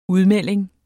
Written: udmelding

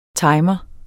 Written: timer

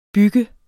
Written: bygger

